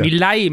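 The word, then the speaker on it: Milei: Und da haben wir auch ein paar Reden beispielsweise, habe ich auch mitgebracht von Javier Mileni oder wie wird er ausgesprochen? – Der Milay, Milay aus Milay. Argentina.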